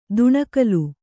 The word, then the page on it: ದುಣಕಲು: duṇakalu